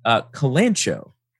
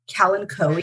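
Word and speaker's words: Kalanchoe: But I was saying, I've always said calancho, because that's how it's spelled. And I don't know that I ever heard it pronounced. – Calancoe, is that what they said? – Yeah.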